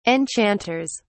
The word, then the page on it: enchanters: British English